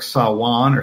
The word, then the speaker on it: Samhain: Yeah, but that's pronounced like Sawan or Sawan or something. And I had wondered, because all us kids in the suburbs are, putting the Samhain tape in our Camaros, and are we pronouncing this right? It turns out we are.